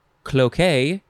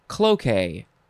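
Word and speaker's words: cloqué: Next is, you could say, cloquet or cloquet. You can emphasize either syllable. It is spelled C-L-O-Q-U-E. You could also put an accent on the E, which is down on the left and up on the right. Cloquet. It's like croquet, but it's not.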